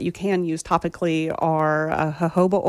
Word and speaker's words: jojoba: How do you say that? – Jojoba, and it's spelled J-O-J-O-B-A, oh, yeah. – Which I mistakenly said jojoba oil for a long time.